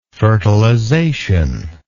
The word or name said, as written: fertilizational